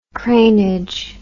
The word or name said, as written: cranage